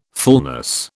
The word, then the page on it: fulness: British English